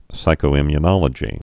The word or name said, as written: psychoimmunologist